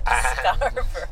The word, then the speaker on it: Scarborough: Listen to that pronunciation. Scarborough. – Proper Scarborough. – Scarborough.